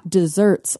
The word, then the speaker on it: deserts: It is DESERTS, spelled like deserts, but pronounced desserts like a deserter because it's the state of deserving something.